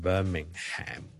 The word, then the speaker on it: Birmingham: 銀荷包的銀呢當是類似 Bellingham Jude Bellingham 咁個 HM 呢係唔需要讀出嚟嘅，咁當然你讀埋出嚟呢都唔係一定錯嘅，如果你是美國人呢可能會讀埋個 Ham 出嚟， Birmingham 呢就可能會讀成 Birmingham 咁樣，咁但如果英國呢大部分人呢個 HM 都係唔讀嘅，今日就講到呢度啦，拜拜。